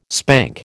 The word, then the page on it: spank: American English